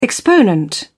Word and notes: exponent: British English

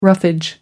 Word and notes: roughage: American English

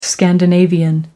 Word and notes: Scandinavian: American English

